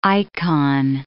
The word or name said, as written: icon